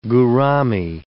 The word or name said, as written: gourami